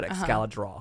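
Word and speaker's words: Excalidraw: Yeah, so ExcalDraw, like Excalibur, but ExcalDraw.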